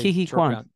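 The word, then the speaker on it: Ke Huy Quan: The dude that played Ki-hi Kwan.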